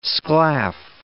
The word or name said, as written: sclaff